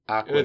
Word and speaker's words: Akureyri: When I hear it pronounced, it is definitely, it's said how it's spelled. – Akureri. Yeah, Akureri. – A-K-U-R-E-Y-R-I.